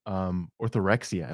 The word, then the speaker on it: orthorexia: how would you describe orthorexia? Am I pronouncing that even correctly? – Yep. You're pronouncing that correctly.